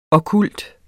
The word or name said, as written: okkult